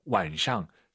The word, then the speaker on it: 晚上: 그리고 아까 남자 선생님께서 읽으신 걸 들어보셨나 모르겠지만, 완상, 사성으로 이 윗상자를 발음하기도 합니다. 선생님, 그렇죠? – 也可以的. – 네, 완상, 완상. 다 되죠, 선생님, 편하게. – 是的. – 좋아요. 이거 부담을 너무 갖지 말았으면 좋겠습니다. 그래도 정확한 발음은 경성이 맞다. 중국인들은 때에 따라서 완상, 사성으로 한다.